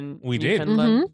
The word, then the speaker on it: Newfoundland: So we learned how to become initiated as a Newfoundlander. Mm-hmm. Yeah, Newfoundlanders. – I did not learn how to pronounce that. – Newfoundland.